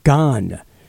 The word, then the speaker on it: Gahan: On the Scoot on the Air Rock Culture calendar, today we wish you a very happy birthday to David Gahan. That's how you pronounce his name.